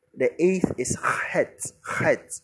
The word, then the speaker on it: Chet: So if you can't pronounce words from your gut, you can say Het. No one would arrest you for that, but it's pronounced from the gut. Chet.